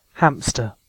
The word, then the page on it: hamster: British English